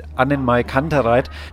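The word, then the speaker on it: AnnenMayKantereit: Ja, neben mir sitzt jetzt Henning, der Sänger der Band Annenmay Kantereit.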